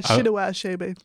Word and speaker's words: Chinua Achebe: I think I've been pronouncing that wrong all my life. – Yeah. Some people have difficulty pronouncing it. Yeah, Chinua Achebe.